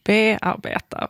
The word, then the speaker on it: bearbeta: Och varför tar det då helt plötsligt betoning i bearbeta? Varför säger vi inte bearbeta? Som vore det rimliga.